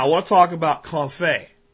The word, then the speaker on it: confit: And if you want to look up information on it, it's spelled French, so it doesn't sound like it's spelled in English phonetically. It's C-O-N-F-I-T, like confit, is how you would phonetically pronounce it, but confit.